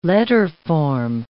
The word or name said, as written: letterform